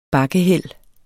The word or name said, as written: bakkehæld